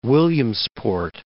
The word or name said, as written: Williamsport